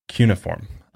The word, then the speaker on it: cuneiform: I've heard it pronounced different ways, but I'm pretty sure it's pronounced cuneiform. Okay. I've looked it up. Trust me. I've done the, how do you pronounce cuneiform? And they said cuneiform. – Are you sure? Should I bring up a YouTube video? It's not cuneiform. It's cuneiform.